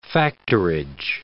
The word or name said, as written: factorage